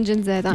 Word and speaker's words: Gen Z: Se volete fare la pronuncia inglese, dice Gen Z.